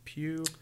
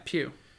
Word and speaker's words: Pugh: Well, seeing as how that has Florence, how do you say her last name? Pew, P-U-G-H. – Yeah, Pew.